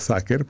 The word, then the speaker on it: Sacer: Se escribe S-A-C de casa E-R. Se pronuncia Sacer porque viene del latín sacro, que hay una contradicción ahí que ya explicaremos.